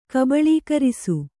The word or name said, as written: ಕಬಳೀಕರಿಸು